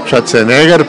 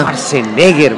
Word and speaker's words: Schwarzenegger: Schwarzenegger, pues así se llama. – Schwarzenegger, vaya.